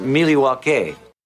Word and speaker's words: Milwaukee: It's from Third Space Brewing, which is right here in Milwaukee, or Milwaukee. – Actually, it's pronounced Milwaukee.